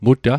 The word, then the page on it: muta: Mi'gmaq